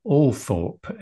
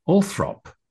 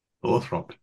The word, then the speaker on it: Althorp: The family estate, of course, is Althorpe, A-L-T-H-O-R-P, yeah. But traditionally pronounced, even by Earl Spencer himself, her brother, Althrop. – Althrop, yeah.